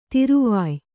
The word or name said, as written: ತಿರುವಾಯ್